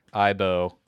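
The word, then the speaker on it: Aibo: Dogs, but with a Z. – And it sounds like... – I. – Yep. – Z. I dog. Ibo.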